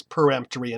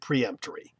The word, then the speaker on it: peremptory: peremptory i think we should pronounce as peremptory and not preemptory. That one's easy to say as peremptory, and we don't need to say preemptory, even though many people do.